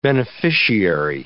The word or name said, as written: beneficiary